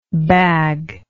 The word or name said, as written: baglike